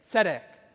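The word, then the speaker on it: tzedek (צֶדֶק): Those are two E's, long E's, making it say tzedek.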